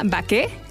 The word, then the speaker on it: Baqué: Se llama Baqué, que viene del anglicismo bake, pero... Okay. Como bien dices, es una panadería francesa con corazón mexicano. Entonces, es baqué con acento en la E.